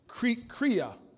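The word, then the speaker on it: chreia: It's the Greek word kria. It's spelled C-H-R-E-I-A. C-H-R-E-I-A, which means lack.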